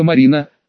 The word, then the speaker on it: Марина: Но обо мне, похоже, не только сестры Ястребовы позаботиться решили, то есть не только Виктория Арсенина и Ника Марина, с ударением на первую «А», но и кто-то из начальства.